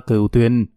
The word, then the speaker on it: cửu tuyền: Đúng đó con, phải đọc là cửu tuyền, không đọc cổ tiền được nghe.